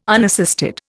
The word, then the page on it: unassisted: Indian